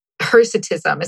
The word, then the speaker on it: hirsutism: hirsutism. Is that how you say it, right? I always read it, but... – it's hirsute, so maybe it's hirsutism.